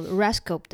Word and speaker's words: RESCOOP: 好啊，嗯、我们的第一站呢是到比利时的布鲁塞尔，嗯、哼拜访一个叫做 RESCOP 的一个、嗯、哼一个组织。然后它的拼法是 R E S C O O P，它这样子念是因为它是荷兰文的发音。